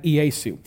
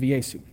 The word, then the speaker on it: Iesu: And in Latin, the name of Jesus was roughly pronounced something like Iesu, which comes from the Greek, which is pronounced very similar to the Latin, something along the lines of Iesu.